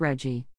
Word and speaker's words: RGGI: It is known as RGGI, and pronounced Reggie.